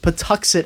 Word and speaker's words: Patuxet: Patuxic? That's the name of the tribe. It's Patuxet. – Yeah. – Patuxet, I think, is the way it's pronounced.